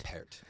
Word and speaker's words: Pärt: See, my favorite story about Arvo, I've always pronounced it Pärt, but is it Pärt? – Pärt, yeah.